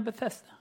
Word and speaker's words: Bethesda: How you pronounce in English? Bethesda? Is that right? Bethesda? Okay, Bethesda.